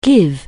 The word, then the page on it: give: anglais